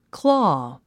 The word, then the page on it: claw: klɔ́ː; クロー